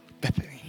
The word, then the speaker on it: Bethany: Bethany.